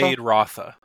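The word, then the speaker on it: Feyd-Rautha: But, like, so Faye Rocca. Is that his name? What is it? Rotha? – Faye Rotha. – Faye Rotha. Okay.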